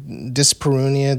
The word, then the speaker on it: dyspareunia: You know, dyspareunia. The idea of like, is that how you pronounce that? – Yeah.